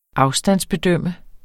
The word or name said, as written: afstandsbedømme